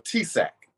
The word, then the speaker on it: TSAHC: So you're investing and you're doing, I don't know how the acronym is pronounced, but it's TSAHC. It's the Texas, the Affordable Housing acronym, essentially, for Texas, correct? – Sure. Oh, TSAC. That's correct. – TSAC. Okay, so TSAC.